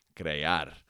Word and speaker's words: crear: Crear, and this is spelled C-R-E-A-R, crear.